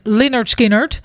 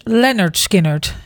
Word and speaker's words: Lynyrd Skynyrd: En dat spreek je zo uit. En ik moet daar elke keer over nadenken, want ik deed altijd zoals iedereen Lynyrd Skynyrd. – Ja. – Maar het is dus Lynyrd Skynyrd.